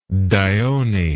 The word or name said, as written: dione